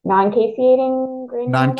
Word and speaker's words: non-caseating granuloma: So they finally just did a spleen biopsy, and that's when they found the, I'm going to mispronounce it, non-caseating granuloma. I didn't butcher it, but yep. Yeah. – You got it.